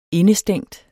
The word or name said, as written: indestængt